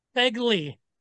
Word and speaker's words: Fegley: And what is the proper way to say your last name? – Fegley. – Okay, Fegley. Got it. – Fegley, yep.